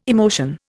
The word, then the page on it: emotion: Indian